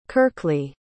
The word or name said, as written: kirkley